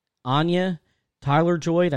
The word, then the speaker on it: Anya Taylor-Joy: And will star Anna Taylor-Joy in the title role alongside Chris Hemsworth. Apparently, I think it's Anya Taylor-Joy, I think that's how you're supposed to pronounce it, is going to be playing Charlize Theron's character.